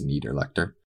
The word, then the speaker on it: Niederlechner: Depending on how we in English pronounce our vowels, he's right that the I would get pronounced, but I'm pretty sure it's Niederlechner.